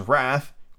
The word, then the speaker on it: wrath: Make the G hard in jeer and jimble, and pronounce wrath to rhyme with bath.